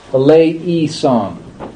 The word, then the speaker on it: eleison: I would pronounce this as A. Well, in the Byzantine form, it's all flattened out to an E sound. So we say eleison.